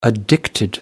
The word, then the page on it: addiction: American English